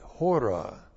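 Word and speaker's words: hora (ὥρα): H long O R A, hora.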